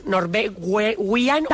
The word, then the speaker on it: Norwegian: Con Norwegian.